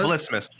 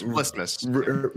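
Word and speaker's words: Vlismas: Vilsmus. – No, I think rhymes with Christmas. V L I S. – It's V L. – I think rhymes with Christmas. Vilsmus.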